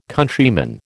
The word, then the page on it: Countryman: American English